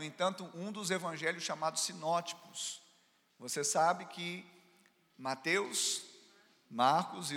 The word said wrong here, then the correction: sinóticos: Sinóticos, tipos, não, ticos.